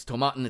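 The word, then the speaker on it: Tomatin: I went to my friendly local neighborhood liquor store the other day, and they had a new scotch on the shelf, Tomaten, not to be confused with tomato tin or whatever. Like I had to look up how to say it because I genuinely was not sure. It's Tomaten.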